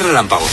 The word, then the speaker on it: relámpago: ¿Quién es relámpago? – Yo. – ¿Y qué pone aquí? Relámpago. Relámpago es una palabra esdrújula. Y las esdrújulas se acentúan en la vocal de la antepenúltima sílaba.